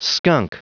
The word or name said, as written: skunk